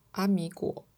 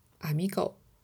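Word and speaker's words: amigo: 朋友在我的语言里读成阿米果，阿米狗。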